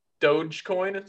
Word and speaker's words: Dogecoin: I'll give you a 1/15,000th of a Dogecoin.